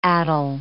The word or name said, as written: addle